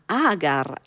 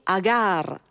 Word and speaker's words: Agar: Così viene definito nel libretto, che chiama Agar, Agar, anzi sentiremo l'accento Agar, Agar, segnala appunto che c'è un ruscello che è stato fatto sgorgare appositamente per dissetare il piccolo Ismaele.